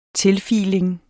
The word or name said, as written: tilfiling